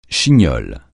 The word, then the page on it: chignole: français